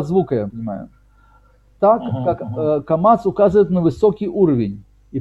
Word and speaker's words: камац: Не комац, да. А камац. Вот, это так, это звучит почти одинаково в сефардском произношении, вот, и поэтому здесь нужно отдельно указать, в чем все-таки между ними разница, как раз об этом указывает Рабейну Бихай.